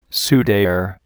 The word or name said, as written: sudare